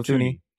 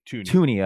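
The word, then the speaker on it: Thuney: Is it Thuny? Is that how you pronounce his name? – Joe Thuny. – Tooney. Okay, I've heard it both ways.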